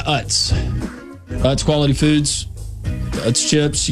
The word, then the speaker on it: Utz: Utz. All around the world. – And I'm guessing that's how you pronounce it. – It is.